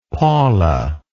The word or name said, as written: Paula